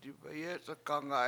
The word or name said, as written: dibeyEc71kgAnga7